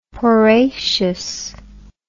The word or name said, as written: porraceous